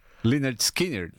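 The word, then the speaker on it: Lynyrd Skynyrd: Liner Skinner. – Leonard Skinner.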